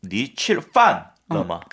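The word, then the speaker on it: 니 칠판러마 (你吃饭了吗): 판, 판. – 니 칠판. 러마. – 그렇죠. 푸푸 발음이에요.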